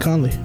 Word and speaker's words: Conley: I'm probably going to say his name wrong because it's Colony or Colony, whatever dude's name is. From, Memphis. – Oh, Mike Conley. – Yeah, Conley.